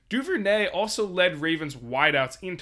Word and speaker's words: Duvernay: What I like coming out of Baltimore, Devin Duvernay, and yes, that is how you pronounce his name, he's had 5 games of double-digit points this season.